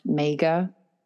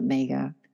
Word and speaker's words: Maga: And it's spelled M-A-G-A, and it could be pronounced Mega. I like to call it Mega, or Major, if you like.